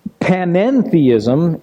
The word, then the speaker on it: panentheism: Panentheism, and the emphasis is on the N in the middle of it, means in the Greek, says that the universe is in God.